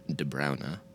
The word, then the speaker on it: De Bruyne: We just happen to believe that the true Flemish pronunciation is more of a De Bruyne.